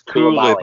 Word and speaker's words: Koulibaly: I'm sure it's my middle son told me say it's Koulibaly.